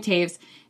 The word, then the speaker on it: Toews: It's called Women Talking, and it's by Miriam Taves, which is spelled T-O-E-W-S, but it's Miriam Taves.